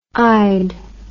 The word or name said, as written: ide